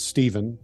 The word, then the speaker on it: Stephen: So my full name is Stephen, but spelled with a PH in the middle, no V.